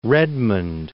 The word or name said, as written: Redmond